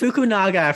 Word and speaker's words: Fukunaga: And like, it's Fukunaga for the record.